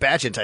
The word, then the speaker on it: Bagent: So Tyson Bagent, that's how you say it, Bagent, I think, right, is how you say it.